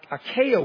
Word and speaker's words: acacia: It was bronze and it was made of acacia wood. I hope I'm pronouncing that right. I don't think I am. It's two C's. Acacia. Thank you.